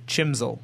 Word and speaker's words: CHMSL: Or feel free to spell it phonetically. Chimsel.